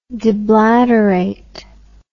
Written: deblateration

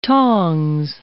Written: tongs

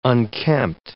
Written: unkempt

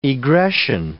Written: egression

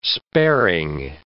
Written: sparing

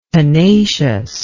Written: pennaceous

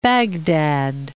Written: Baghdad

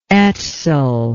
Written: Etzel

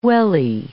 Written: wellie

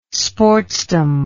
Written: sportsdom

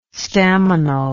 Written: staminal

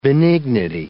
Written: benignity

